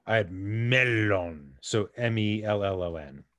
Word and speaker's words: mellon: It is indeed spelled melon, M-E-L-L-O-N. but in Sindarin, which is the name of the language that Tolkien comes up with, it is pronounced melon.